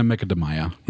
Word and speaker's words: Macadamia: Das wird ja eigentlich Macadamia ausgesprochen. Ja, auf eine Macadamia.